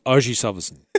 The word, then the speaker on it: Ajay Salvesen: It's a correct pronunciation is Ajay Salvesen.